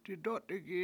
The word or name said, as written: didOtigi7